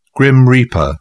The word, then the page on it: grim reaper: British English